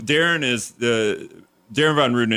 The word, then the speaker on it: Darin Von Ruden: Okay, so Darren Von Ruden is the, did I say it right? – The president of the Wisconsin Farmers Union, yes.